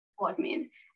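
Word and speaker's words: Mortmain: But then they switched to Charlotte's perspective, where she was considering reaching out to Henry when they were going initially to meet Mortman? How do you pronounce it? – Mortman.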